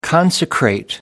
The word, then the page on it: consecrate: American English